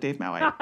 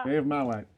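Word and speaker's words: davemaoite: No, but it's there's this well-known, apparently well-known geophysicist Dave? Named Dave Mau. So they called it Dave Mauite. – Dave Mauite.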